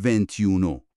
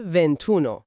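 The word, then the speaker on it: ventuno: پس ونتی به اضافه اونو نمیشه ونتیونو. – بلکه میشه ونتونو.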